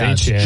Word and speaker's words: Etienne: Etienne, Travis Etienne. – Yeah, it's Etienne.